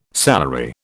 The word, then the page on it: salary: British English